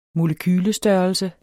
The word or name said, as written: molekyle